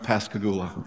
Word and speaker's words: Pascagoula: Pascagoula. And he was asked one time why he used that, and he said, it rhymes with hallelujah.